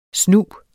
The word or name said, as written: snu